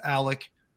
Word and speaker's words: Alex: There's uh, Alec, and I know it's spelled Alex, but it's pronounced Alec. We get we get all kinds of shit when people say it's spelled Alex and you're saying Alec because we were told that's how it's pronounced.